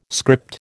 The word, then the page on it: script: American English